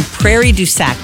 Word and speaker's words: Prairie du Sac: Few moments, we heard from our manager, Steve, who let us know that there is a woman in Prairie du Sac.